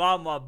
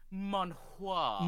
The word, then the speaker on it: manhwa: Manhwa. – Manhwa. That's an early podcast meme. Used to get on us because we wouldn't pronounce it right. And he's like, actually, it's pronounced manhwa.